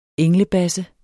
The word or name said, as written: englebasse